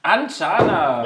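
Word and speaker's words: Andschana: Anchana. – Anchana.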